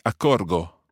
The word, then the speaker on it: accorgo: And if you look to the I form, it would be accorgo. – Accorgo, sì. – So I've got that hard G sound there, and that's where we take the subjunctive form from.